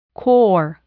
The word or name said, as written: core